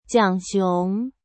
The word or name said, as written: Jiang Xiong